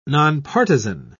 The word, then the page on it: nonpartizan: British English